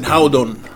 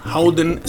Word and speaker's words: Hovedoen: It's spelled H O V E D O E N Hovedoen Social Club, but you pronounce it Howdon, Howden Social Club.